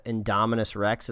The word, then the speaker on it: Indominus Rex: Some people were kind of mad that they finally revealed the Indominus Rex. Is that how you say it? – Yeah.